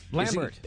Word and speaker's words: Lambert: Is it Lambert or Lambert? – Lambert. – Okay, so it is Lambert, so he's not pronouncing it like a damn Frenchman.